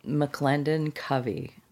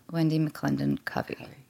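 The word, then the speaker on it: McLendon-Covey: And we've all been, full disclosure, debating on how to say your name. – Oh, McClendon Covey. – Covey. – Covey, yeah. – Wendy McClendon Covey.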